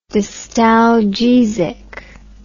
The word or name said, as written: distalgesic